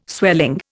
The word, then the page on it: swelling: Indian